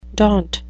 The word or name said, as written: daunt